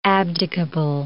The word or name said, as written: abdicable